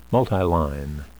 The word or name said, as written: multiline